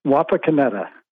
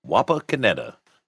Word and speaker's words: Wapakoneta: It's Wapakoneta. – Wapakoneta.